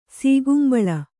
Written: ಸೀಕುಂಬಳ

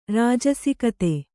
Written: ರಾಜಸಿಕತೆ

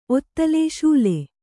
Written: ಸೂಲೆ

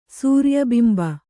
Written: ಸೂರ್ಯಬಿಂಬ

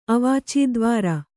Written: ಅವಾಚೀದ್ವಾರ

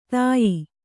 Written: ತೇಯು

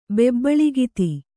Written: ಬೆಬ್ಬಳಿಗಿತಿ

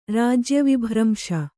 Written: ರಾಜ್ಯವಿಭ್ರಂಶ

